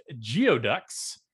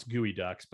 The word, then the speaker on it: geoduck: Evergreen State College Geoducks. – The hell's a geoduck? – Oh, actually, I pronounce gooey ducks, but I always say geoducks.